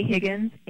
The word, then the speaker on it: Higgins: Best email address for me, and I'll spell it phonetically, is A Higgins,